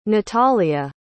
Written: nathalia

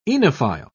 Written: oenophile

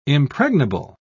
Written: impregnable

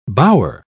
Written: bower

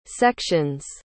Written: sections

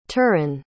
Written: turin

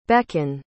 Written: becan

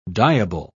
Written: dyeable

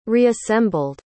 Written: reassembled

